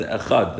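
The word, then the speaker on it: echad (אחד): In other words, you want to make sure you pronounce the echad, that there's a kamatz under the chet.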